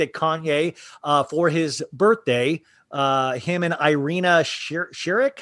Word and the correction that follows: Irina Shayk: Irina Shayk.